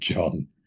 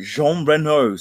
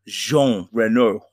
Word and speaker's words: Jean Reno: You just, the first way you sound like, I swear it sounded like you said John. – No, Jean Reno. Sorry, I didn't put enough Gallic kind of soft J on that. Jean Reno.